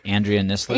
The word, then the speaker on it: Andrea Nisler: Am I saying your name right, Andrea Nisler? – You actually are, yeah.